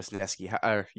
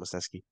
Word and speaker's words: Wesneski: And then my other dark horse is the guy we talked about maybe 25 minutes ago, Hayden Wesneski. Or Wesneski. Yeah, that's how you say his name, Wesneski.